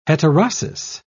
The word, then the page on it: heterosis: British English